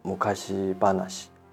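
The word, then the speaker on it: 昔話: 日语发音叫做“ 木卡西巴纳西”，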